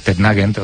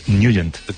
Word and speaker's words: Nugent: Hombre, siempre lo hemos llamado en el barrio Tetnagent o Tetnagent. – Claro, ¿no? Yo era de Tetnagent. – Pero la pronunciación correcta es Nugent. – Te fuiste a Washington y descubriste que era Nugent.